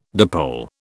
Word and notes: dipole: British English

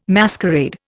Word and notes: masquerade: Indian

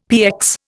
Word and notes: PX: Indian

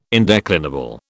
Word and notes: indeclinable: British English

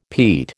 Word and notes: Pete: American English

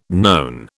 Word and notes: known: British English

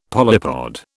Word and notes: polypod: British English

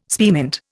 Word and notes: spearmint: Indian

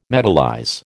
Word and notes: metallize: American English

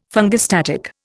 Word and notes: fungistatic: Indian

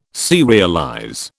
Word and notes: serialize: British English

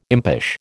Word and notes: impish: American English

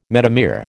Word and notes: metamere: American English

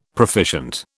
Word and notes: proficient: British English

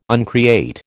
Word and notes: uncreate: American English

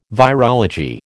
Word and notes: virology: American English

